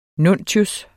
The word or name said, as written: nuntius